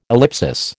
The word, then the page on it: ellipsis: American English